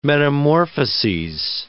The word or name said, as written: metamorphoses